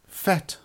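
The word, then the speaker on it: PhET: One of the major additions has been some excellent interactive activities that were created by an organisation called FET. I think you pronounce it like that. It stands for Physics Education Technology.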